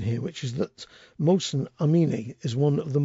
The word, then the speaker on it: Mohsen: His name is Mohsen. I think that's how you pronounce it, M-O-H-S-E-N.